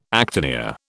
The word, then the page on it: actinia: British English